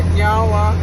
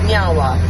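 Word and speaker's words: Niá:wen: So, in our language, we say nyawa, which means thank you. – Nyawa. – Nyawa. – I said it right? – Yeah, you did.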